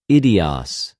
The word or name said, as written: ἰδιος